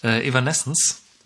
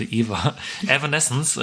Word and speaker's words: Evanescence: Evanescence, sehr komisch ausgesprochen gerade, Evanescence, ich glaube, das ist für viele Leute auf jeden Fall ein Highlight.